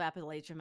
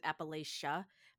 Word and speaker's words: Appalachia: By the way, I am going to say it incorrectly because where I grew up in the foothills, we just all said Appalachia, but I feel like it's more accurate to say Appalachia with a ch.